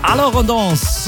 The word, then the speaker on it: Alors on danse: Alorondance.